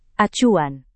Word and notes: acuan: indonesian

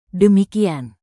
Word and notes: demikian: indonesian

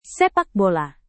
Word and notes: sepakbola: Indonesian

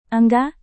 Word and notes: enggak: indonesian

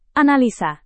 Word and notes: analisa: indonesian

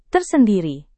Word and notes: tersendiri: Indonesian

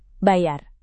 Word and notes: bayar: indonesian

